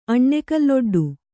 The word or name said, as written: ಅಣ್ಣೆಕಲ್ಲೊಡ್ಡು